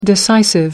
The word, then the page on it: decisive: American English